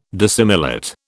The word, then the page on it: dissimilate: British English